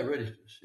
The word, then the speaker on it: reditus: So Christian liturgy is also then viditus. Viditus, how do you pronounce it? – Yeah, reditus. Yeah. Reditus. No, also, it's essentially reditus.